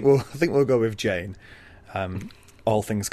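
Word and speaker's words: Jeyne: It's actually I've been calling her Jean for ages, but I think it's supposed to be Jane, because there's a little passage where Theon's in his, you know, he's sort of crazy thinking to himself. He says, yeah. Jane rhymes with pain, which is pretty cool.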